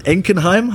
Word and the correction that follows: Linkenheim: habe ich es richtig gesagt? Linkenheim. Na, Linkenheim.